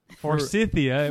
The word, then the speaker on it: Forsythia: I said it wrong. – Forsythia. – There's no N.